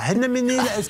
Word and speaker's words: Anneménil: Ah ben oui, d'accord, ça se prononce comme ça. Anneménil,